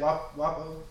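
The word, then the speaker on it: Wopo: Wapo? – Yep. – With their passing, they both passed or got murdered Monday afternoon-ish, I guess. Both gunned down. I really haven't been keeping up with Jimmy Wapo's case, but XXX's case...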